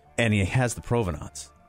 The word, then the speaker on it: provenance: How do you say that word? – Provenance. – Providence. – Provenance. Providence. Whatever.